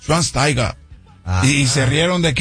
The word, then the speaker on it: Schweinsteiger: Y un día como hoy, 4 de agosto, pero de 1984, tiene mi edad, nace Bastian Schweinsteiger, uno de los mejores mediocampistas alemanes de su generación.